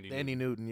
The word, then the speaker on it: Thandie Newton: Thandie Newton, yeah.